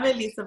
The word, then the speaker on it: Eliza: Oh, I said that wrong, Elisa. Y'all saw how confident I was? Wow, I am. – No worries. It's spelled like that, but yes. So I'm Elisa Volkin.